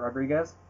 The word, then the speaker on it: Rodriguez: That is right, Robert Rodriguez.